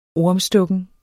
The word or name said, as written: ormstukken